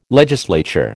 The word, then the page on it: legislature: American English